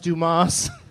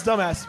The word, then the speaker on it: Dumas: I think it's pronounced Dumas. – No, it's Dumbass, pretty sure. – Dumbass. – It's and, French, dude.